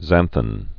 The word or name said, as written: xanthan gum